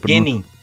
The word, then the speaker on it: Genin: Então, o Tsu, Tsukikeiji, que é um jovem Genin, é Genin? Como é que se, li, se pronuncia? – Genin.